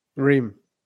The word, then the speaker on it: Reims: That's R-E-I-M-S. Go ahead. How do I say it? – Reims. – Oh, it's just Reims. It's like Tim Reims.